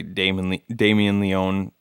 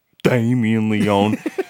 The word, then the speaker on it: Damien Leone: Yeah, I like, think that that is such an awesome choice by Damien Leone to make the character... – Say it right. – The fucking Damien Leone.